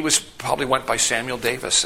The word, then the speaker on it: Davies: And that is, Samuel Davies was a Welsh. His parents were Welsh. And they came from Wales, moved to Pennsylvania. But in Wales, Davies is not pronounced Davies. It's pronounced Davis.